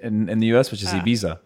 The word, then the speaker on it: Ibiza: Most people just go, Ibiza. – right? – We say Ibiza. – You say Ibiza. – Like a z. – Ibiza. Yeah. – Like a pizza.